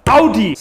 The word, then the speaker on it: Audi: So, the first wrong car name, so many people say Audi, when it's actually pronounced Audi.